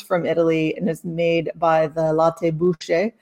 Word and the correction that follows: Busche: or Busche, sorry. I should know that. I'm in Italy. I should know how to pronounce things.